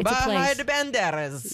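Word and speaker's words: Bahía de Banderas: Bahia de Banderas? – Yes, you said it right. Perfect. It's Bahia de Banderas.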